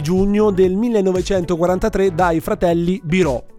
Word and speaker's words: Biro: Cioè, in realtà Biro, scusa, non okay, Biro. Perché infatti l'accento è chiuso. – Ah, ok. – Quindi Biro.